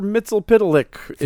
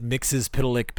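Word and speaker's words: Mxyzptlk: I pronounce it Mixes Pitalik because I read that in a Who's Who.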